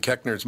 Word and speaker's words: Koechner: It's pronounced Koechner, It's spelled Koechner. – Oh, Koechner, yeah.